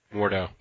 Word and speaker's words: Mordo: There was a really good panel in um, in that volume that I'm still reading, where uh, Baron Modo Mordo, I can't pronounce his freaking name. Mordo. He's thank you.